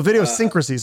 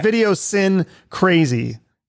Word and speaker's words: Videosyncrasy: Oh, Videosyncrasy. Sorry, I said it wrong. It looks like Video Sin Crazy.